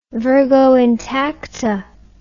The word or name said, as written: virgo intacta